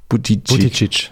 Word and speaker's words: Buttigieg: einen gewissen Vorteil hat gegen jemanden, wie zum Beispiel Pete, wie spricht man ihn aus? – Buttigieg. – Buttigieg.